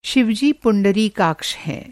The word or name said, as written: पुंडरीकाक्ष